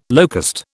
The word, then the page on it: locust: British English